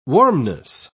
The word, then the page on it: warmness: British English